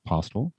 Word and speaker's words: Postil: Name's Mike Postil, P-O-S-T-I-L, pronounced Postil. Think of the friendlier version of Hostel Postil.